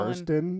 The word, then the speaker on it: Burstyn: Burstyn.